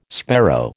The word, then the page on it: sparrow: American English